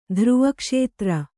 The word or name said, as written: ಧ್ರುವಕ್ಷೇತ್ರ